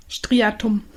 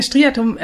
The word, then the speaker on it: Striatum: Blinkt ein Like, blinkt es im Belohnungszentrum, dem sogenannten ventralen Striatum. Spreche ich das richtig aus? – Ich glaube ja, Striatum. – Mit Betonung auf liegt doch T. – Ja, Striatum, kennst du das, wenn du einen Begriff gerade gelernt hast und plötzlich taucht der wirklich überall auf? – Wie mit unserer Analyse genau.